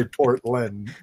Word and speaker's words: Portland: Is it Portland or Portland? – I would say Portland myself. I put the emphasis on the first syllable.